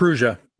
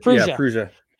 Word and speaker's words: Prusa: Prusa. Yeah. Prusa.